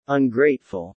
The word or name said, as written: ungrateful